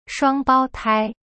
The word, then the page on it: 双胞胎: shuang bao tai